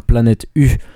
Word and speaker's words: Planet Y: Y bueno, un grupo que se llama Planet U. Planet U, pero que se escribe Planet Y, pero la Y en danés se pronuncia U.